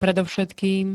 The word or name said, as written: predovšetkým